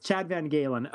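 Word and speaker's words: Chad VanGaalen: Oh, you butchered so many names there. It's Chad Van Galen.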